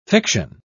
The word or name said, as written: fiction